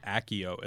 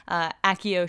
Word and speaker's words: Accio: I did write down one thing when she was on Tumblr, and how do you pronounce it from Harry Potter, that the summoning spell, is it Accio? – I think Accio, at least that's what they say in the movies.